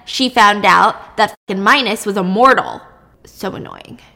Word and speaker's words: Minos: Yeah, I thought his name was pronounced Minos because that's how it's spelled, but it's Minos.